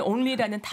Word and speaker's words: only: 가끔씩 L하고 N이 바뀌어서 이제 발음이 될 때가 있어요. – 어. – 그래서 자연스럽게 N 먼저 L 다음. Only.